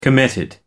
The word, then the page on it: committed: American English